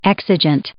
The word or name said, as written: exigent